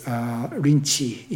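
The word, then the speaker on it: Linji: This expression, the person who can be master in any place, came from Rinzai is Rinchi in Chinese pronunciation.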